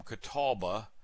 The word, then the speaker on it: catalpa: And I would say when I was growing up, people would kind of say it catalpa, that you wouldn't hear the L. It's hard to pronounce the L. So catalpa, and now it's become catalba, which sounds like the Indian tribe in North Carolina.